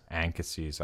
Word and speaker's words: Anchises: Well, how would you say that? Anchises? – Anchises, I would say.